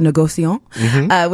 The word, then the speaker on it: négociant: That's the way to pronounce it, négociant, which is pretty much the maker of wine.